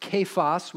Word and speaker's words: Cephas: First to Cephas, and if you see that name, you're going, hmm, I don't know. That word could be pronounced kephas, which means stone.